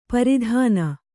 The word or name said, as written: ಪ್ರತನ